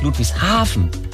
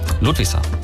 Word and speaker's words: Ludwigshafen: Nicht Ludwigshafen. Nicht die Betonung auf Hafen, sondern auf Ludwig. Ludwigshafen.